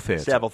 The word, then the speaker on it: Savile: Savile Theater. Okay, yeah. I didn't want to say it like Savile Row, because it's spelled differently.